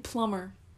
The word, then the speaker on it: plumber: Because, like, in plumber. – Plumber. – Plumber. Where's yep. the B? Where did the B? – Where did the B go? – Where?